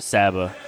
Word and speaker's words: Saba: It's actually pronounced Saba. It's like short for sabotage.